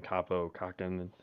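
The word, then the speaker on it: Kaapo Kähkönen: I also selected Kaapo Kekkonen, and that's how you say his name, Kaapo Kekkonen, yeah.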